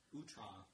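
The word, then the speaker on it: outré: some of his more outre works. – Outre. – Is that how you say it? – It's outre, yeah, because there's a U.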